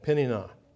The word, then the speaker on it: Peninnah: I looked up the correct pronunciation of that, Peninnah.